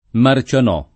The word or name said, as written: Marcianò